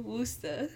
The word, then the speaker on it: Worcester: Or they don't even say it right either. – I know, Worcester.